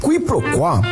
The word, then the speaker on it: quiproquó: Q-U-I-P-R-O-Q-U-O com acento agudo. – Quiproquó, também informalmente pronunciada como quiproquó, é a ação de confundir uma coisa com outra, ou o desentendimento decorrente dessa confusão.